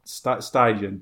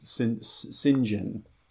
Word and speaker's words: St. John: I thought it was Stygian, but apparently it's just Sinjin. Or you can go to YouTube and look up James St. John Smythe, Roger Moore, and you can hear him pronounce it.